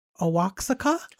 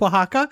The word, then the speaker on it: Oaxaca: I was doing news reads for Bill Post back in the day, and I got some copy, and I pronounced it Owaxaca. And it's Oaxaca, and I did it, and he never let me forget that.